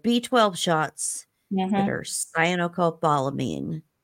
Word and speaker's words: Cyanocobalamin: Cyanocobalamin.